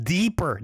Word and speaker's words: Deeper: Why does he even have a song called Diaper? Or am I pronouncing it? Wait. I think it's pronounced deeper, not diaper.